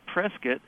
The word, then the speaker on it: Prescott: It's Prescott, as in biscuit.